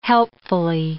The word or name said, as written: helpfully